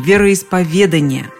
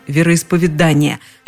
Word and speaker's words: вероисповедание: Все словари настаивают именно на таком ударении – вероисповедание. Словарь ударений Резниченко даже и вариантов других не приводит. Орфоэпический словарь под редакцией Аванесова все-таки упоминает, что встречается вариант вероисповедания – но рядом с ним стоит строгая помета «неправильно».